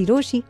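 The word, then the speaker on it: Rochie: मेरा नाम है मिर्ची रोशी, नाम की स्पेलिंग है RO CHIE.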